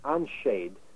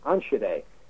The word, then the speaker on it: Enschede: to a city that looks like it should be pronounced Anshade, but it's not. It's pronounced Anshade. It's spelled E-N-S-C-H-E-D-E.